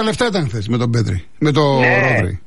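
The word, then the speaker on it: Pedri: Πέδρη, το τονίζω, Πέδρη, Κομπαρσί που είναι 18-19 χρόνο, πόσο είναι? – 19 το...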